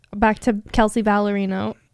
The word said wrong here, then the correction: Ballerini: What is the pronunciation? Ballerini. – Yeah, ends in an I, not an O.